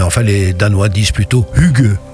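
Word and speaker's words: hygge: Alors, tu me corriges hein, si je me trompe, le hugue. – Voilà, hugue ou hugue, selon qu'on essaie de prononcer ce mot à la française. – C'est écrit avec un Y.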